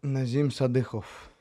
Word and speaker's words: Nazim Sadykhov: Once again, guys, Naz Sadykhov, and say it for the fans, just so I'm not butchering it, and they can say it how you say it. – Nazim Sadykhov.